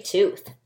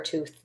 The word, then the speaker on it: tooth: So tooth has a TH sound at the end. And when we have TH, we say th. Otherwise, you might say Beth lost her toot. Toot.